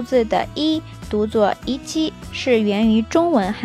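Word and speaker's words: いち: 数字一在日语当中可以读一七，取第一个发音，两个组合在一起就变成了一，表示好的意思。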